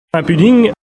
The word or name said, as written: Bodink